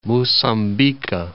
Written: Mocambique

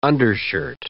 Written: undershirt

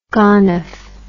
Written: gonoph

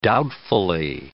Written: doubtfully

